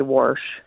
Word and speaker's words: wash: And I just wanted to make a comment about you, the way you pronounced WASH. In Iowa, they say Warsh.